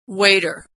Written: waiter

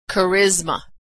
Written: charisma

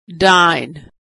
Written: dine

